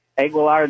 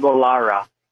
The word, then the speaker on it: Aguilar: And Aguilar, they were calling him Aguilara.